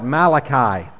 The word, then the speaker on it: Malachi: This book has a variety of pronunciations. When we lived in Ireland, in the British Isles, people refer to this book as Malachi. And the name is still a common name in parts of Ireland. There are people who are named Malachi. And so you have it. I've heard one person even pronounce it Malachi. But we're going to call it Malachi, I think the way most of us have heard it through the years.